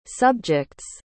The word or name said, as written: subject's